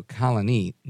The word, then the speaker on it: Colonie: Uh, like Colony, but instead of with an I, uh, a Y at the end, it's with an IE and the uh, accent on the IE so Colonie, New York.